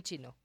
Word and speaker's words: Qin: ¿cómo se pronuncia Qin? Q-I-N, en chino.